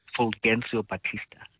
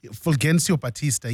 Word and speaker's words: Fulgencio Batista: I'm struggling to pronounce their name, but Fulgencio Batista. – Fulgencio Batista, yes.